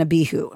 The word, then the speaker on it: Abihu: And it's specifically the abrupt death of Aaron's sons, Nadab and Abihu. Am I pronouncing that right? – Yes.